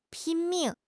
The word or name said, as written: 拼命